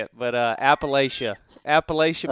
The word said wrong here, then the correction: Appalachian: Did I say that right? – Oh, you said it wrong. – Appalachia. – Way wrong. Yes, Appalachian. – Appalachian. – The best way to remember how to say it is if you call it Appalachian, I'll throw an apple at you.